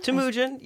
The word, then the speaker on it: Temujin: And am I saying that right in Mongolian? – Temujin, yeah. Yes. I mean, there's there are regional differences between the way that you can say it, but mm-hmm. it's Temujin or Temujin, mm-hmm.